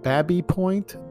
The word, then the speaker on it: Baby Point: The land was developed into the current neighborhood in the early 20th century, and the name is pronounced by locals as Babby Point, to rhyme with tabby or cabby, in an approximation of how James Baby pronounced his surname.